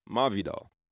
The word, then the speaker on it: Masvidal: And yes, it's pronounced Masvidal.